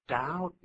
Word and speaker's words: doubt: 后头那个 to 可以省略掉，直接放原形动词 doubt me. 这个 doubt 是这么拼的，d o u b t b 不发音的，d o u b t doubt.